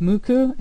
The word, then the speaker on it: MUCC: and I've always heard it pronounced Mucu, M-U-C-C.